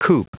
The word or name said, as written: coop